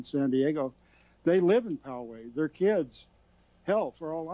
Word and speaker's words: Poway: Because Poway, that's the pronunciation. – Okay, Poway, thank you, Poway. I had a 50-50 chance. I blew it.